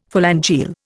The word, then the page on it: phalangeal: Indian